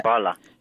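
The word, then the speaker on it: școală: Is it pronounced Shkola? – Right. – Yeah. – Shkola. – Shkola. Yes. Romanian for school.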